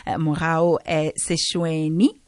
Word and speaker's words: Mogau Seshoene: So go via social media, or you can also purchase the book, The Lazy Makoti's Guide to the Kitchen, Mogau Seshoene.